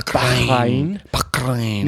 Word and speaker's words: Bahrain: They'll say Bahrain.